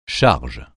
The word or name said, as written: charge